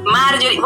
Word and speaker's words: Marjohrie: Marjorie, voy a deletrear mi nombre para que sepan cómo se escribe. Marjorie se escribe M-A R-J-O-H, R-I-E, con J-O-H, por favor, si no, no la voy a encontrar.